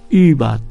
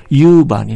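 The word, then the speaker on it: über: これは日本語にないでしょ、うん、でドイツ語でね、ウーバーって言葉があるんですね。 U の上にちょんちょん書いて BER、英語のオーバーですけども、ウーバーって言うんですね。これ日本人の学生はね、なかなか発音しにくいんですよ。ユーバーになっちゃうんです。カタカナのユーしかないから、ユーバー、ユーちょっとないからユーバーになる。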